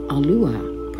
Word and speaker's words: Aluah: I will use Aluah, pronounced A-Lu-Ah, which means Almighty One, singular.